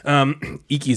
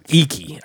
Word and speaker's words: Iki: Icky is actually I-K-I. Icky. I think it's Icky. Pronounced Icky.